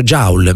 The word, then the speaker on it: Joule: E se questa persona invece di prendere, si pronuncia Joule, mi dite? Bene, vi ringrazio. Quando combattete la mia ignoranza, Joule, avevo detto Joule, benissimo, se si pronuncia Joule, vi credo.